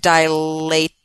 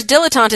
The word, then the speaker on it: dilatant: Yeah, like because it looks like dilate, except dilatant. – The Glavin. – Dilatant. – Oh, cool. Okay. – Because, of course, dilettante is spelled completely differently. It's like French. – Yeah, it is French. Yeah. – But phonetically, it certainly seems like it could be dilettante.